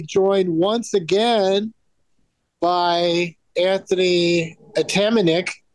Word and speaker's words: Atamanuik: I just told you it's Atamanuik. Atamanuik.